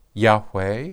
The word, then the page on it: yhwh: Hebrew